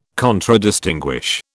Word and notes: Contradistinguish: British English

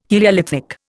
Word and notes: heliolithic: Indian

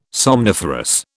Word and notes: somniferous: British English

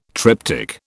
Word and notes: triptyque: British English

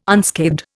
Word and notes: unscathed: Indian

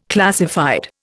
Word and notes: classified: Indian

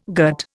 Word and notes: gut: Indian

